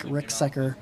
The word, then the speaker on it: Ricksecker: So anybody listening at home, too, if you're interested about shadow creatures and that stuff, Mike Ricksecker. It's spelled kind of weird, but it's like Rick Seeker, kind of, but Secker.